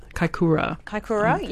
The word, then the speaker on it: Kaikoura: Kaikoura, yes.